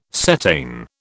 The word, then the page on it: cetane: British English